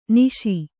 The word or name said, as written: ನಿಷಾ